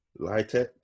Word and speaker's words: LITK: Litek, LITK.